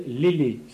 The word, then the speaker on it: eslai: Dans l'adoubement laïque, la cérémonie était suivie de ce qu'on appelle l'élé. Ça s'écrit E-S-L-A-I.